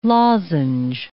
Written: lozenge

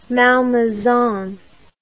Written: malmaison

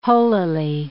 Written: holily

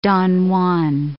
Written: Don Juan

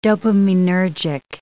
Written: dopaminergic

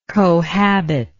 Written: cohabitor

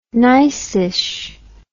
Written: niceish

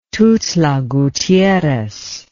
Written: Tuxtla Gutierrez

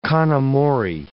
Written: con amore

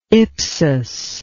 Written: ipsus

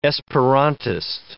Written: Esperantist

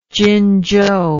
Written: Jinzhou

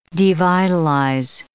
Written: devitalize

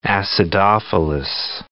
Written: acidophilus

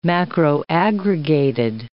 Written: macroaggregated

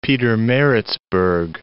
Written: Pietermaritzburg

